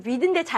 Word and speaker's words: read: 쌤, read인데 잘못 읽으셨어요. 아니야. 과거 시제일 때는 read라고 읽어요.